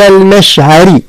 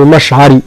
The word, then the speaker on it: المشعر: المشعري، مش كما نطقوها في الدارجة سيدة من المشعري، لا المشعر، لا المشعري، كلها مرقة حتى الراء لأنها مكسورة، عند المشعر الحرام.